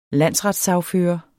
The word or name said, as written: landsretssagfører